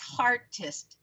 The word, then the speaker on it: Heartist: It's Heartist. I want to make sure I'm saying that right, because it's spelt like heart with an IST.